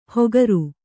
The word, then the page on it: ಹೊಗರು: hogaru